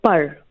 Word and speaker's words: par: It's called par. – Par. – P-A-R. Par. Par, which just means wings.